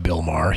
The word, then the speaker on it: Maher: For the uninitiated or those with very short memories, Bill Maher, that's B-I-L-L-M-A-H-E-R, pronounced Bill Maher,